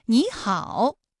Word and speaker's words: 你好: 第一个音节的三声变成二声，比如说你好，哎，这两个音节的实际声调啊都是三声，但是我们在说话的时候，第一个音节你它就变成二声了，也就是 214 加 214 直接演变成了 35 加 214，你好。